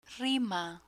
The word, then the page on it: rima: spanish